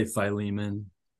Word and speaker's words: Philemon: Is there any, I don't see Philemon. How do you say it? – I say Philemon.